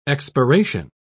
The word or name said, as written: expiration